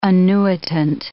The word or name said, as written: annuitant